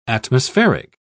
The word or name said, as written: atmospheric